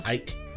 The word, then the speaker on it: Icke: David Icke. – Ike. – It's spelled Icke.